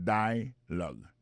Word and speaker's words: dialogue: Alors, mot dialogue, ça c'est dialogue. Ça nous a remarqué là, nous a remarqué que l'accent tonique là tombé sous premier syllabe là.